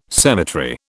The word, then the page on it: cemetery: British English